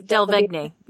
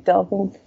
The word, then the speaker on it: Delevingne: So it's Cara Delevingne or Cara Delevingne. Yeah. – Oh, she pronounces everything. Okay. Delevingne. Okay. Would not have gone that direction with it, but okay.